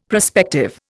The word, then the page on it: prospective: Indian